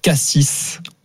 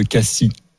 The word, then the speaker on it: Cassis: Alors, les gens qui prononcent Cassis au lieu de Cassis. – Pour la ville. – Exactement. – Et on dit pas Cassis ? – Et non. – Mais non, on dit Cassis.